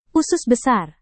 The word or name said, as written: usus besar